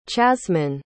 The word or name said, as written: chazmyne